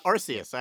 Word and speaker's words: Arceus: I don't know how you pronounce that one. – Arceus. I actually had to look that up today.